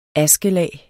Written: askelag

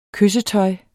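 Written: kyssetøj

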